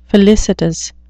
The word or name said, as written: felicitous